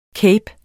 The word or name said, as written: CAPERNES